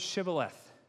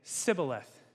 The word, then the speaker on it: Shibboleth: Say the word Shibboleth. For some reason, the people of Ephraim could not say that correctly. They would say Sibboleth. They couldn't say the sh part of it.